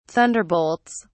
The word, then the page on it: thunderbolts: British English